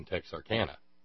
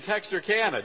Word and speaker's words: Texarkana: Well, it is exciting to be in Texarkana. Did I say it correctly? All right, good.